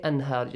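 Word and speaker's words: Anhar (أنهار): Anhar, just read it clearly. Anhar, nun is clearly pronounced.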